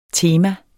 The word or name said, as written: tema